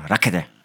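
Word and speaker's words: Rackete: Das, was die gemacht hat, die Frau Rakete, was übrigens ein sehr lustiger Name ist, Es wird aber gleich anders ausgesprochen. Rakete. – Rakete.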